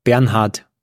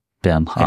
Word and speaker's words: Bernhard: Actually, Olivier is not pronounced how the German Oliver would be pronounced. So it's not really that German, but Bernhard. – Bernhard. – I think that's pronounced the German way.